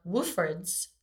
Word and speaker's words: Wolford's: Will Hester, owner, head trainer of Wolford's Dog Training. Did I say that right? – Yes. – Like Wolf. – Correct. – Wolf. – That's it.